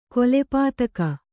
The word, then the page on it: ಕೊಲೆಪಾತಕಿ: kannada